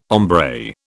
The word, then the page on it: hombre: British English